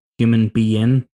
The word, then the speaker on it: Human Be-In: But um, so uh, I, would you wear the the the human being be, uh, it's pronounced the Human Be-In, mm.